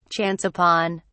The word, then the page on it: chance upon: British English